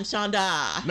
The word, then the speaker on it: Shonda: I'm Shonda. – Not Chandra. Shonda. It's Shonda.